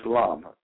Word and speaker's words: Lam: which is LUM. That's L-U-M. Now, most people spell it L-A-M, but it's pronounced LUM.